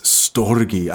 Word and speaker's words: storge: So another word that they had for love was storge. I think that's how you pronounce it. It's spelled S-T-O-R-G-E. Storge.